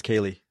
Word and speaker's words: Kali: Yeah, it's spelled like Callie, but it's Kaylee. – Okay, I see her character spelled Kaylee, and her name is K-A-L-I.